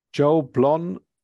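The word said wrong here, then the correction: Jole Blon: or Shenandoah? – Joli Blon. – Is it Joli Blon?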